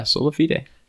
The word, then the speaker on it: sola fide: Sola, did I say that right? Fide? – Yeah, sola fide.